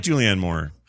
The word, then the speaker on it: Julianne Moore: That's, I'm sorry, Julianne Moore. – Oh, Julianne Moore. – Did I say Juliette Moore? – Yes. Oh, I like Julianne Moore.